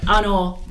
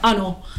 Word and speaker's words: Anna: On my very first day, I remember you had to say your name, introduce yourself, and I pronounced it Anor. That's literally how Anor, and everyone laughed, like, because obviously they're all like up and Anor, and you know, pronounce things properly.